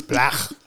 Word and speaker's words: Blach: Yeah, no, it's Blach. It was originally pronounced Blach. – Was it back in the day? – Yeah. – Okay. – And you have to wait till you get to the C, or it's you say it incorrectly.